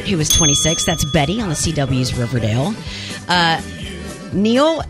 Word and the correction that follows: Niall: Is it Neil? – Niall.